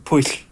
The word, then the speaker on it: Pwyll: Like, there's a, in the Mabinogion, there's a tale of this king whose his name is spelled P-W-Y-L-L. It's pronounced Puyll.